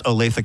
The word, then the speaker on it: Olathe: And I can tell you, Elena, it is pronounced Olathe, Kansas, because I was recently there and I mispronounced it. And I was corrected.